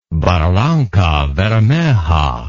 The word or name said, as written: barrancabermeja